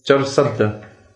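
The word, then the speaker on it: Charsadda: Charsada.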